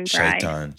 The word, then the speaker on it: Shaitan: And I want to transition into the book right at this moment here, because how do you pronounce it? Is it the Shaitan? – It's the Shaitan. – Shaitan. – Yeah. Right. It's like the, similar to Satan, basically, in Christianity.